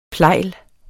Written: plejle

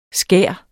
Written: skær

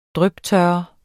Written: dryptørre

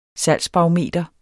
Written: salgsbarometer

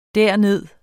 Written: derned

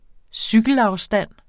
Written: cykelafstand

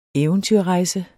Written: EVENTYRREJSEN

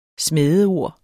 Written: smædeord

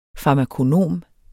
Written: FARMAKONOM

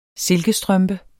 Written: silkestrømpe

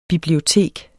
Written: bibliotek

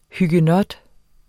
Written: huguenot

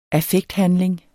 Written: affekthandling

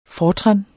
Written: fortran

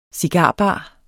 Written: cigarbar

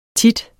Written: tit

